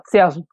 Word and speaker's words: Cergy: Salji. And I'm not even saying it right. Salji. They'll tell me I'm wrong too. They were like, no, Salji. I'm like, okay, fine. – But even in that, C-E-R-G-Y.